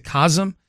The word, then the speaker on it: COSM: I guess they pronounce it COSM. COSM is how it's spelled.